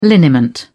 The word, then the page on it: liniment: British English